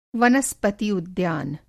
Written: वनस्पति-उद्यान

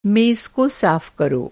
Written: कर

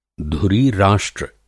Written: धुरी-राष्ट्र

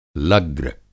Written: लग्र

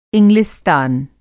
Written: इंग्लिस्तान

